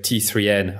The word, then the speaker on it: T3N: So how do you pronounce the last magazine I said, like T3N? How do you pronounce it in German? – I think it's totally correctly. T3N. It's great.